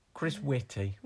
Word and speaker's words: Whitty: I can never pronounce his last name. Chris Whitty. – Yeah. – I wanted to say Wiki, but that's the character from Transformers.